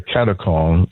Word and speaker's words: katechon: Katechon.